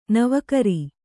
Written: ನವುಕರಿ

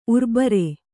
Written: ಊರುಬೇರು

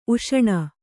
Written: ಊಷಣೆ

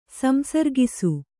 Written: ಸಂಸರ್ಗಿಸು

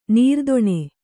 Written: ನಿರ್ಧನ

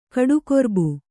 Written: ಕಡುಗೊರ್ಬು

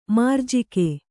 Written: ಮೌರಜಿಗ

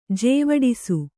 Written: ಜೇವಡಿಸು

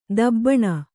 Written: ತೀಬನು

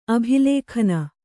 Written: ಅಭಿಲೇಖನ

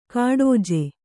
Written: ಗೂಢಜ

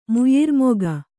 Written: ಮುಯ್ಯೀರ್ಮೊಗ

